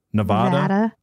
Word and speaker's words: Nevada: Nevada with a hard A. Nevada. Vada. I don't know. Anyway. – Nevada.